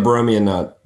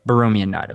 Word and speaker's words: Borromean: and you can correct my pronunciation because I don't actually know how to correctly say it. What is it? The Borromean knot? – Yeah, the Borromean knot. – Borromean knot. Okay, that sounds better.